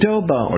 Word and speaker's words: adobo: How do you spell abado? A-B-B? – A-D... Or I mispronounced adobo.